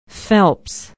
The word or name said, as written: phelps